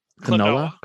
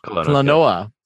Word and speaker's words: Klonoa: Kelowna? Is that how I pronounce that? – Klonoa. – Klonoa.